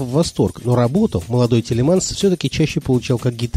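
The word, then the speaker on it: Thielemans: которого весь мир знает под именем Тутс Телеманс. На самом деле, его полное имя – Жан-Батист Фредерик Исидор Телеманс. В Америке его фамилию произносят как Тилманс, а Тутс – это прозвище, которое молодому музыканту дали приятели в послевоенной Бельгии, чтобы его имя звучало как-то более джазово.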